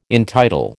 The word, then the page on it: entitle: American English